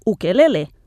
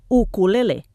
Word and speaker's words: ukulele: Pel que fa a la pronúncia, la més habitual és ukelele, però com que cada vegada és més conegut entre nosaltres, en català oriental en podríem acabar dient ukalela. En hawaià s'escriu i pronuncia ukulele, i segons la llegenda ve de uku i lele, puça saltadora, per la velocitat amb què es belluguen els dits dels intèrprets.